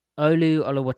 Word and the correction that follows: Olu: It's actually Ulu. It's Ulu. The Seahawks put out a pronunciation guy.